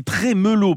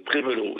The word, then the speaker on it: Trémélo: Ok, et on dit Trémélo, parce qu'il n'y a qu'un accent. – C'est exact, oui, Trémélo, oui. – Trémélo, on le prononce bel et bien comme ça.